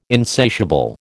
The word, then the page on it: insatiable: American English